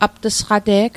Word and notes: apt'sqate'g: aptîsqate'k; Mi'gmaq

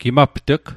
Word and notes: gimapt'g: Mi'gmaq

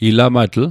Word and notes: ilamatl: Mi'gmaq

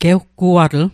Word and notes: gewguatl: Mi'gmaq

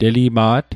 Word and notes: telima't: Mi'gmaq

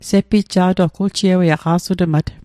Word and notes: sepitja'toq: Mi'gmaq

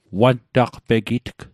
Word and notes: wantaqpegitg: Mi'gmaq

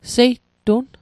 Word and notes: seitun: Mi'gmaq

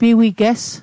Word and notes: piwiges: Mi'gmaq

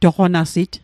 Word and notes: toqonasit: Mi'gmaq

